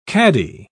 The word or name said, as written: caddy